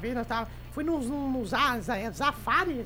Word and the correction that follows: Safari: Zafari, que fala, como é que fala? Que fica vendo os leões, sim, que fica vendo os bichos. – Safari.